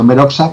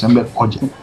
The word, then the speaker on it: Enver Hoxha: Escrito Oxa, pero bueno, yo siempre he dicho Enver Oxa, pero a partir ya hace unos días decidí que lo iba a pronunciar como Dios manda y es Enver Hoxha.